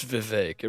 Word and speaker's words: Vivek: He's talking with somebody, and somebody brings up Vivek, and Bill Maher goes, "It's Vivek. It rhymes with cake."